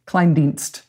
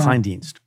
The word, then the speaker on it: Kleindienst: and I never can pronounce his name properly, but Kleindienst? – Kleindienst. – Yeah. Kleindienst.